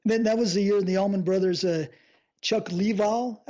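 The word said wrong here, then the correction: Leavell: how do you say his last name? – Chuck Leval. – He joined them. – Or Chuck Lavelle, excuse me. – Lavelle.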